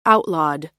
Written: outlawed

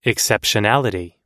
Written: exceptionality

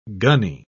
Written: gunny

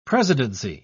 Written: presidency

